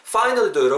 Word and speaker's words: finally: you've 이렇게 발음하시고 finally become 하실 때도 finally도 여러분 F로 시작하잖아요. finally라고 하시면 안 된다는 거예요. finally 발음 다르죠? finally finally 다르죠? 입술 끌기면서 바람이 밑으로 새야 돼요.